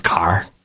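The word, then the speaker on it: Kaa: And I also want to tie it to Ka. Do you remember Ka? Pronounced Car. It's the snake's name in the Jungle Book.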